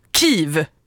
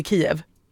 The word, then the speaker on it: Kyjiv: Kyiv. Kiv. Men det stavas K-Y-J-I-V. – Men det ska uttalas Kiv. – Kiv, som kniv. Absolut inte Kiev.